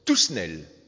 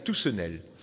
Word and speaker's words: Toussenel: Ce livre est écrit par un gars qui s'appelle Alphonse, je vais le prononcer à la française, Toussenel. À la méridionale, c'est Toussenel.